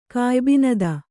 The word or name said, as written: ಕಾಯ್ಬಿನದ